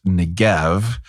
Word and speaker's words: Negev: It's also more commonly spelled and pronounced Negev.